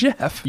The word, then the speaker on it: Geoff: Jeff Johns. – But it's got the Geo in it, so. – When have you ever seen G-E-O-F-F pronounced Geoff? – I don't know. In it's Jeff.